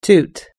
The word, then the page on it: toot: American English